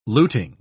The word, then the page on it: luting: British English